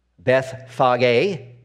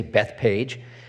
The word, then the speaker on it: Bethphage: Today we walk to Bethpage, Bethphage, as it might be pronounced in the Greek, but in English we say Bethpage, a village on the Mount of Olives at Bethpage, Jesus rides into Jerusalem.